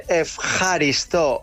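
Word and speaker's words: efjaristo (ευχαριστώ): ¿Cómo se dice gracias? – Esta es la difícil. – A ver. – Se dice efjaristo. Efjaristo.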